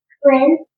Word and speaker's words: Wren: Ren. – How do you spell it? – W-R-E-N. – W-R-E-N? – Yeah.